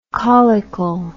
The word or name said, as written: caulicle